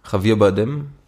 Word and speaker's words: Javier Bardem: Und äh, wenn ich, äh, ich weiß wieder nicht genau, Javier Bardem.